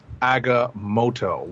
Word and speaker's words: Agamotto: So I'm glad that Marvel Studios has come to our aid and has shown us exactly how we're supposed to pronounce Agamotto, one of the Vishanti. – Right. – Take like it Shang-Chi. Away, Roddy Cat. – Yeah, like Shang-Chi. But yes, so he uses the eye of Agamotto, which is the Time Stone, and this decides to go back in time.